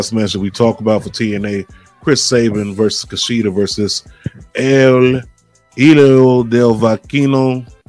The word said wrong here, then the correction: El Hijo del Vikingo: No, I said that wrong. Pronounce it. – Yeah. – El Hijo del Vikingo.